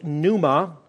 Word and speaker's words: pneuma: The Greek word is pneuma, with a silent P on the front of it, P-N-E-U-M-A, and that can be translated spirit or breath or wind.